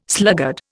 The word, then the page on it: sluggard: Indian